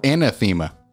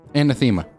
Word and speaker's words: anathema: Antithema. Or anathema. Yeah. Anathema. Definitely it. – Anathema. – Anathema. – Yeah, you didn't say that right.